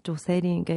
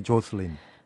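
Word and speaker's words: Jocelyn: 일본에서 가타카나로 조세린, 그러니까 원래 이름이 조세린, 음. 조셀린. 음. – 조세... 원 발음 이제 조슬린. – 조슬린. – 네. – 조슬린인데 그 제일 가까운, 가까운 발음이 일본 말로 조세린이니까 네.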